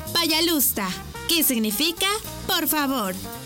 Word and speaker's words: Пожалуйста: Y se dice Пожалуйста. ¿Cómo? Пожалуйста. Una vez más. Пожалуйста. Y se escribe Пожалуйста. P-A-Y-A-L-U-S-T-A.